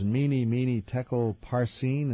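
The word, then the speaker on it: Mene Mene Tekel Parsin: How do you say these words, meeny, meeny, tekel, parsin? Is that how you say that? – Close enough.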